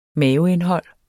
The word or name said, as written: maveindhold